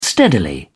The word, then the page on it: steadily: British English